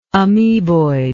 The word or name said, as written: ameboidism